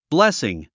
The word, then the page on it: blessing: blésiŋ; ブレスィン